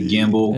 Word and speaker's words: gimbal: A gimbal? Is that how you say it? Gimbal? – Yeah, it's a gimbal.